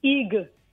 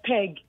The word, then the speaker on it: pig: the word pig, pig, is pronounced as peg. And we said, no. So that's not how we call it here.